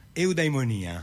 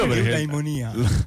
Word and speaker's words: Eudaimonia: Eudaimonia. – Ok, lo vedi come lo pronuncia, anche con gli accenti giusti. – Eudaimonia. – Lui lo pronuncia bene.